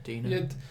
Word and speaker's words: DeNA: Nintendo have teamed up with DENA Games, Dina, I think. Would you pronounce it Dina? – I would, yeah. Dina.